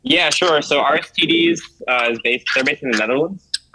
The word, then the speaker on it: Aristides: Aristides. Is that your, no, it's not bad. – Okay. – Yeah. I'm pretty sure it's Aristides. It's like the Greek god.